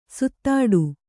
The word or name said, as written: ಸುತ್ತಾಡು